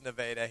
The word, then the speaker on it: Nevada: Again, for the national press, we understand that it's spelled Nevada, but it's pronounced Nevada here.